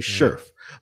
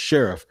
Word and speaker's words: Scherff: So Brandon Scherff, and I used to say Scherff, but it is pronounced Scherff, the all-pro guard from Washington that they signed to solidify the right guard position.